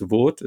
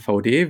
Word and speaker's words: VAUDE: Werden sich dann oft gefragt haben, okay, ist das jetzt eine französische Marke? Wie spricht man das aus? Ist das VAUDE? Ist das in Österreich sehr gern auch die Variante VAUDE? Bin ich auch drüber gestolpert? Nein, wir heißen VAUDE.